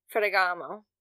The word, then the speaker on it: Ferragamo: I think they were Ferragamo or something like that. I can't pronounce it right because I can't roll my R's. But Ferragamo. That sounds better. Just give it an Italian accent.